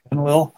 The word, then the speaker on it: Enlil: Whereas the Enlil group, I think that's the name, I think I'm getting the name right. – Enlil with an L. – Mm-hmm. Enlil. Okay.